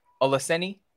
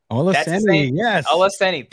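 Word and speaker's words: Olaseni: I hope I'm not butchering it. But Bam Iladeli Oluseni. – Oluseni. – There you go.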